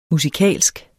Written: musikalsk

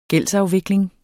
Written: gældsafvikling

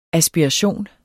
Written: aspiration

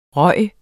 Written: ryger

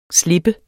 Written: slippers

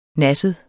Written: nasset

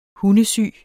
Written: hundesyg

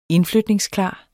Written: indflytningsklar

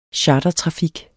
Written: chartertrafik

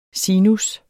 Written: sinus

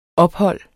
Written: ophold

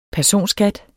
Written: personskat

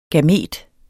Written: gamet

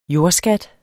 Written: jordskat

